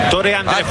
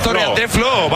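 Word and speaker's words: Tore André Flo: Torre André Flo. – Torre André Flo, vale.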